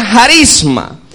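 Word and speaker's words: jarisma (χάρισμα): Los dones, mi hermano, decíamos que hay una palabra eh, griega que se pronuncia jarisma.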